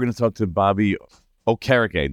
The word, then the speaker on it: Okereke: Okereke, yep. Yeah, it's okay. It's from Nigerian descent. Yeah, it's the correct pronunciation.